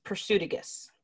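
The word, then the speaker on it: Prasutagus: No, I said that wrong. It's Persudagus. Persudagus.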